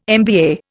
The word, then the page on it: MBA: Indian